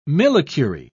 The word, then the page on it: millicurie: British English